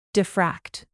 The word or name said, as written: diffract